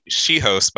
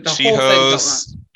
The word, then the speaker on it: Joswiak: It's She-Host, by the way. – See, I knew. No, it felt terrible. – She-Host, Joswiak. – That one I got? No, it wasn't J. The J isn't pronounced.